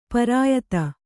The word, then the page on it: ಪರಾಯತ: parāyata